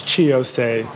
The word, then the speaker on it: Chi Ossé: We have Mr. Shai Osei. Did I pronounce that correctly? – Is he on? It's Chi Osei.